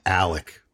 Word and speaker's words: Alex: Murdoch is spelled M-U-R-D-A-U-G-H, but I think it's pronounced Murdoch. And Alex, A-L-E-X, is pronounced Alec.